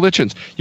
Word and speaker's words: Litchens: I now gift the Tuesday nights to Michael Litchens. – Michael Litchens. – You can rhymes with kitchens.